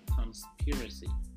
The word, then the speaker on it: conspiracy: The first word is conspiracy. Conspiracy. I'm going to spell that for you. C-O-N-S-P-I-R-A-C-Y. Conspiracy. conspiracy. Let me spell that again. C-O-N-S-P-I-R-A-C-Y. Conspiracy. Okay, conspiracy. The stress is on the second syllable.